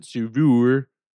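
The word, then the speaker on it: Saveur: I believe that's how you pronounce it, Savour. Savour, it's like fur, save, Savour.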